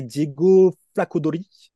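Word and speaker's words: Flaccadori: Euh, ceux qui ont une année supplémentaire, on a Maodolo, euh, Giordano Bortolani, pardon, Ismaël Kamagate, Gian Paolo Ricci et Diego Flacodori, si je ne me trompe pas, Lucas, sur la prononciation. Non, on est bien Bref.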